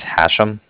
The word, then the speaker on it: Hashem: So my name is Hashem. That's H-A-S. H-E-M as in mother at the end.